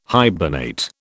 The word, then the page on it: hibernate: British English